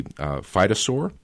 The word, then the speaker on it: phytosaur: Yeah, and 220 million years ago, you have this thing called, I believe you pronounce it, a a phytosaur? – That's correct, yeah.